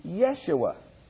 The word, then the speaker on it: Yeshua: There is no J in Hebrew, so it couldn't really be Jesus. It's a Y. And the name is pronounced Yeshua.